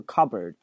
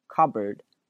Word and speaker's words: cupboard: I open the door of the cupboard，很多学生会把这个单词读成这个 cupboard，首先那个 p 是不发音的，用之前讲过爆破音，后面如果出一个辅音，你看这个 p 后面接一个辅音 b b，这个前面这个 p 就不发音了，所以它不会读成 cupboard，它读的是 cupboard，而且那个 b o a r d 直接略读成 r cupboard，不是 cupboard，我们会读成 cupboard。